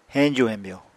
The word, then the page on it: heñju-hemyo: Apatani